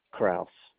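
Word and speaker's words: Crouse: Okay, it's C-R-O-U-S-E, Kraus. – Okay. And your first name? – It's Richard, C-H-A-R-D.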